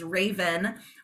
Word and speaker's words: Rayvan: So I'm one of those lucky kids that my parents decided to merge family names and combine it into one name, which is Raven, R-A-Y-V-A-N. It sounds like the bird, but it's spelled completely different.